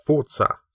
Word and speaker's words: Forza: Well, I think in because it's Italian, isn't it? I think you're meant to put the accent on the Z. – Yeah, Forza.